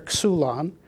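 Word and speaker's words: xulon (ξύλον): It is xulon. You can see how to say it here, xulon.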